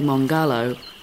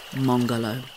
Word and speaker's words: Mongarlowe: However, Buduwang elder Uncle Noel Butler tells us that the way we say Mongolow is all wrong. In the local Duraga language, the stress between the syllables is even, so it should be Mongolow.